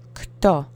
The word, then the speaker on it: kto: And keep the O sound monosyllabic. Let's repeat three times. Kto, kto, kto.